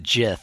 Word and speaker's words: Gith: Well, some people pronounce it Jith.